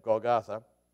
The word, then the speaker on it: Golgotha: But anyway, Golgotha.